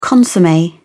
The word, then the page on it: consomme: British English